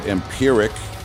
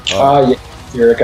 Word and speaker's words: Empyrrhic: And something I wanted to talk about too was I noticed that you had a project this year, if I pronounce it right, Empyrrhic. – Empyrrhic, I think. – Empyrrhic for the listeners.